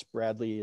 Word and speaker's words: Bradlee: Skinner, and that's Bradley is B-R-A-D-L-E-E.